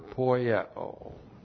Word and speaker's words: poieo: We have the verb poieo. And we've seen this word many times. P-O-I-E, long O.